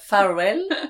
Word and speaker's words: Farvel: Donc c'est pas le cap Farvel. c'est le cap adieu. C'est le cap adieu, c'est le cap farvel. – F A R V E L. – Ouais, comme ça se prononce.